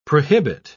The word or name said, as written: prohibit